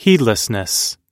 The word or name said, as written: heedlessness